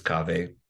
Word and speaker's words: Kaveh: My name is Kaveh.